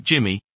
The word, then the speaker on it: GMM-MI: To accurately estimate MI from a finite number of samples, we present GMM-MI, pronounced "Jimmy", an algorithm based on Gaussian mixture models that can be applied to both discrete and continuous settings.